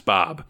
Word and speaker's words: Boab: Yes it's it's a uh, Bob spelled a uh, B O A B, which is how the uh, Scots pronounce Bob, basically.